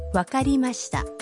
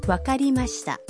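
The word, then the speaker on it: Wakarimashita (わかりました): Wakarimashita. Wakarimashita.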